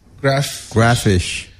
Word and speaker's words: Graffish: Is that how you say it? – Graffish. – Graffish. – Graffish. – Graffish. – Like graffiti, but fish.